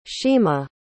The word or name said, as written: shima